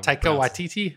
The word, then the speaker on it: Taika Waititi: Like what you director of Taika Waititi, or however you call it. – Taika Waititi? – Yeah, that's the better pronunciation.